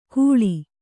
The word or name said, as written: ಘೂಳಿ